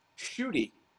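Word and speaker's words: Ncuti: But apparently it's pronounced Shooty. His first name is pronounced Shooty.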